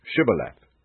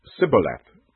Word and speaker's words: Shibboleth: If he answered no, they would ask him to say, "Shibboleth." If he said, "Sibboleth," not being able to give the proper pronunciation, they would seize him and kill him at the fords of the Jordan.